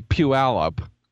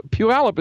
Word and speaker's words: Puyallup: So like if I just say Puyallup, which is one of our local place names, you could guess for a good long while about how to spell Puyallup, and you would always be wrong. It's not spelled any way like it sounds. So Puyallup is spelled P-U-Y-A-L-L-U-P. That makes no sense.